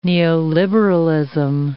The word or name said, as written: neoliberalism